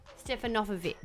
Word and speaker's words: Stefanovic: Is that how I say her name? – Stefanovic. – Oh, there you go. – Because it's the emphasis that wasn't like Carl Stefanovic's last name, because that's been Aussieified.